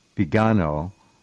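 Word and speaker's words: Viganò: Vigano, it looks like Vigano to everyone. I don't know. You pronounce it a little differently. You have the correct pronunciation, but Bishop Vigano, right? – Vigano.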